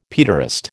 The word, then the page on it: paederast: American English